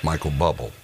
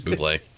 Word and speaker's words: Bublé: Of course, I call him Michael Bubble. – No, it's Bublé.